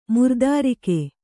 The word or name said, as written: ಮಿರುತಾರಗೆ